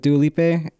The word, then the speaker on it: Dua Lipa: Dude, you should finish it because like the Prisoner songs with Dua Lipa, like that's how you pronounce her name.